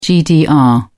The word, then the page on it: gdr: British English